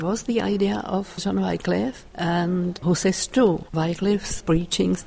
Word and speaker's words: Wycliffe: John Wycliffe, or the British say Wycliffe.